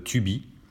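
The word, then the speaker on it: Tubie: Alors pour pallier à ce, ce souci, en fait, j'ai découvert une application qui s'appelle Tubi. Alors elle s'écrit T-U-B-I-E. T-U-B-I-E.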